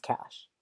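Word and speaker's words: cache: you're a fucking idiot because it's pronounced cash.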